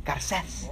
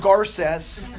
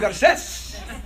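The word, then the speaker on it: Garcés: I found out my name is spelled with a little accent, Garces. So I've been saying it all wrong. So it's not Gio Garces. Gio Garces.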